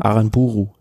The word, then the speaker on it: Aranburu: Kollegen Alex Aranab, wie spricht man das? Aranburu? Aranburu. Ja, ähm, gewonnen.